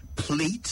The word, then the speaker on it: plait: Well, it's spelled P-L-A-I-T, but it's pronounced plait in British English. The Americans, however, say plate, some even say play.